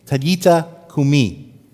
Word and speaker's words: talita kumi: So that's the correct way to pronounce in Aramaic what Jesus said. Taliti, talita kumi.